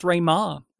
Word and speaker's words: Meagher: His name's actually pronounced Raymar. So there you go. His last name is confusing. – This guy should have come from Wales if he wants it pronounced like that. The way that he's spelt his name and the way that's pronounced do not match up.